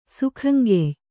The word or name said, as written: ಸೇಕಂಗೆಯ್